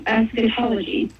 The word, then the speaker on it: eschatology: If I'm pronouncing it right, like eschatology.